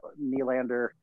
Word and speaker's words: Nylander: And, you know, as long as they have Tavares, Nylander, yes, I said that right? – Yep.